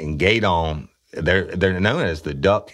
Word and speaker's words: Gueydan: at the Barn Lounge in Gaydon, Louisiana. And that's spelled G-U-E-Y-D-A-N, Louisiana. Now, let me tell you about Gaydon.